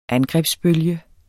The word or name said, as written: angrebsbølge